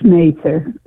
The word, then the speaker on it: Mather: And it's Mather. That's the way to pronounce Mather.